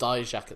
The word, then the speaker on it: Dijak: Dijak. – It's just Dijak at this point.